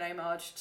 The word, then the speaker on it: homage: And then the really other bit of trivia that I had was that there's a scene that's apparently an homage to The Godfather. – It's an homage. I think it's homage. – Because it's French, so you don't pronounce the H. Homage.